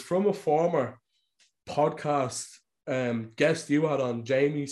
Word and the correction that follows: Jaime: Segura. Is that how you pronounce his name? – Jaime Segura.